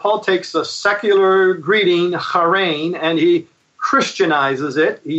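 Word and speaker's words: harein (χαίρειν): So, for instance, the greeting of that day, if I could explain it for a minute, in Greek, it was a word which would be pronounced like this, harein, harein.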